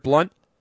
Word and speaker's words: Blount: Well, because it's pronounced that way, but it looks Blount. – It looks like Blount, yeah.